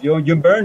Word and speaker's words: Byrne: No, el del escritor, güey, John Byrne, lo dije bien, güey.